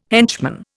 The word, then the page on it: henchman: Indian